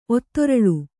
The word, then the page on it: ಒತ್ತೊರಳು: Kannada